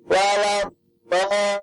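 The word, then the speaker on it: Wala bol (وَلَا الضَّالِّينَ): Nah, ketika walau bolnya ini, kalau bisa jangan kita loskan, Pak. Maksudnya loskan itu kita bacanya seperti A biasa. Nah, ini diloskan. Ya, yang lebih tepat kita bacanya dengan tetap mengangkat lidah kita bagian belakang.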